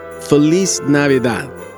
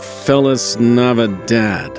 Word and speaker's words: Feliz Navidad: Well, first of all, it's pronounced Feliz Navidad, not Felis Navidad.